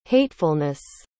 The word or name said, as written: hatefulness